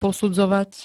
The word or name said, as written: posudzovať